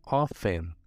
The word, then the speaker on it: often: E por último, often. Que muita gente fala often, often. Esse T é mudo, tá?